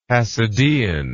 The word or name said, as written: hasidean